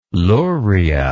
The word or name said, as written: luria